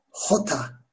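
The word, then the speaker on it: jota: Harfin okunuşu hota, hota. Yine biraz böyle gırtlağa yakın bir yerden geliyor kazıyarak böyle. Yine Arapçadaki H harfi gibi hota.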